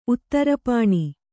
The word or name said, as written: ಉತ್ತರಪಾಣಿ